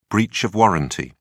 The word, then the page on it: breach of warranty: British English